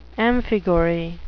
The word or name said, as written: amphigory